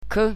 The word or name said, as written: k